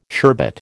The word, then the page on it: sherbet: American English